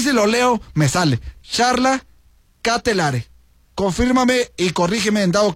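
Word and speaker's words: Charles De Ketelaere: Pero a bote pronto dice Charla Ketelare. Ándale, así se dice. Gracias a quien me envió este mensajito, le agradezco muchísimo. Así se pronuncia exactamente.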